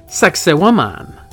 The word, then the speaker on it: Sacsayhuaman: This place is spelled Sacsayhuaman, but if you say it right, it sounds like a sexy woman. Sacsayhuaman.